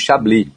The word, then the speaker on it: Chablis: Chablis, se escreve Chablis, mas se diz Chablis.